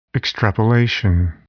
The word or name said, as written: extrapolation